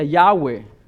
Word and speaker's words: Yahweh: y fusionar una palabra, la palabra se pronuncia Yahweh. No es ese el nombre literal, es la pronunciación.